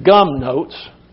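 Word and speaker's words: gumnotes (γυμνότης): Looks like it says gum notes, but it's pronounced gumnotes. G-U-M-N-O-T-E-S.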